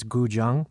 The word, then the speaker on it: guzheng: And Ian, the next track I wanted to play, this was one of my favorite tracks on the EP, and I believe the pronunciation is guzheng, which is a traditional Chinese zither instrument, and it's traditionally used, you know, to make this very melodic kind of music.